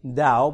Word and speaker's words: Tao: Okay, pronounced with a D, Tao, but spelt with a T.